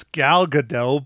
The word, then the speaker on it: Gal Gadot: We went over the names before we started recording, and you still didn't pronounce Gal Gadot right. – Yeah, no, we did go over the names, and I but I think it's Gail Gadot, dude. – It's not Gail Gadot, it's Gal Gadot, but it's Gail Gadot.